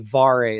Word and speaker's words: Vare: I think it's the Vare is how it's pronounced, or the Vare trophy.